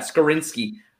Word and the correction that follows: Skoronski: Um, Skoronski.